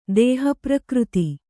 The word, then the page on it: ದೇಹಪ್ರಕೃತಿ: dēha prakřti